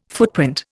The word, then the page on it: footprint: Indian